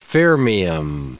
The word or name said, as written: fermium